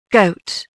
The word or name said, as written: goat